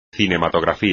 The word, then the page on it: cinematografía: espagnol